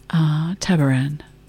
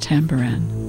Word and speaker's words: Tabaran: Tabaran, sometimes pronounced Tambaran.